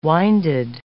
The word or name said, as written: winded